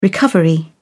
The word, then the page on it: recovery: British English